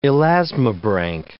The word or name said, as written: elasmobranch